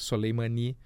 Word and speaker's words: Soleimani: Embora o assassinato do Soleimani, essa é a pronúncia correta, Soleimani, tenha sacudido, chacoalhado o Oriente Médio.